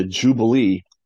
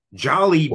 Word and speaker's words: Jollibee: Maybe we'll go to the Jubilee Jollibee. – While we're up there. – Jollibee.